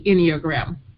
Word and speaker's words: Enneagram: What is the Enneagram? – All right. Well, first the pronunciation, it's Ennea, which means 9, and gram, which means a logo, a picture, or so it's a 9 picture, if I can put it like that.